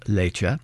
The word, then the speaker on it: Leite: again spelled L-E-I-T-E, pronounced Lecce,